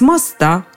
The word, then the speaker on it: моста: Итак, если хотите действовать наверняка, ударяйте на последний слог. Мост с моста, под мостом, за мостом, на мосту.